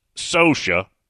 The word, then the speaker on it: Saucier: Mississippi has many other places, mainly on the coast, that are pronounced, for example, something that looks like Gautier, G-A-U-T-I-E-R, is Gautier. Saucier, S-A-U-C-I-E-R, is Socia.